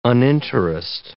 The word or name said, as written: uninterest